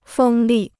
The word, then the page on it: 风力: fēng lì